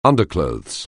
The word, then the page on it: underclothes: anglais